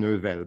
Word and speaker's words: Neuvel: There are two sounds in my name, one in each. One in the first name, one in the last name, that people who were born and raised in English normally can't pronounce at all. So there's the nasal N in Sylvain and the U in Neuvel. So my name is sort of supposed to be pronounced Sylvain Neuvel, but no one does that.